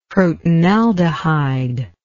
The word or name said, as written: crotonaldehyde